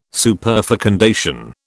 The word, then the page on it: superfecundation: British English